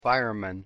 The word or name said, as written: fireman